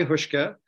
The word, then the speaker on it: Huska: Kai, say your name for us and tell us a little bit about yourself. – Yeah, no, thanks. Thanks, Frances, for having me. My name is Kai Huska.